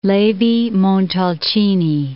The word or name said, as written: Levi-Montalcini